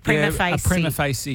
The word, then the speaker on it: Prima facie: Prima facie.